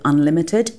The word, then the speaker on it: UNLTD: Elsewhere in the United Kingdom, the first thing to do is to get in touch with an organisation called Unlimited, the Foundation for Social Entrepreneurs. It's spelled UNLTD.